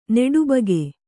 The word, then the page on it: ನೋಟುಬುಕ್ಕು: nōṭu bukku